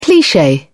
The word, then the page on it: cliche: British English